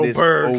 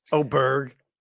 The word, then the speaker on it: Åberg: Folks, it Oberg. Is Åberg. We're that. Now on the fourth pronunciation. – Did you get the memo? Åberg.